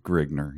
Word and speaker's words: Grignr: Or and then, so Grignr, are we going to be okay with saying it that way? – Yeah, Grignr. yeah, that's how it... That... I think it is spelled a couple different ways, but I believe Grignr, yes.